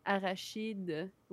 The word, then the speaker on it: arachide: Yeah, it's because you guys do a R, like hard CH, arachnid. Arachide, we do the soft.